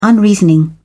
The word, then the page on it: unreasoning: British English